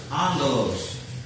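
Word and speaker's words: angelos (ἄγγελος): The other Greek word that archangel is derived from is angelos, angelos, which is spelled A-G-G-E-L-O-S, which means to bring tidings, a messenger.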